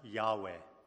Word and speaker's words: Yahweh: In the Hebrew, you see, the word for Lord is pronounced Yahweh.